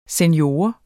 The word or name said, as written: senior